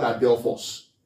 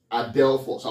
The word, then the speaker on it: adelphos: Now the word there, brethren, in the Greek is the word adelphos. Adelphos. I'll spell that for you as well. A-D-E-L-P-H-O-S. That should be correct. Adelphos. A-D-E-L-P-H-O-S.